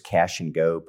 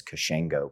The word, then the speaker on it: Cachengo: The original pronunciation was cash and go, but people thought we were processing payroll checks. And and then one of the guys just removed the hyphens and it just kind of rolled off the tongue as Kashengo.